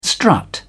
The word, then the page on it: strut: British English